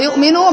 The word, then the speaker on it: يؤمنوا: يؤمنون، او هنا هتبقى يؤمنوا، بنحط الف. الالف دي ايه؟ تكتب ولا تنطق.